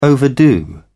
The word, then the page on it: overdo: British English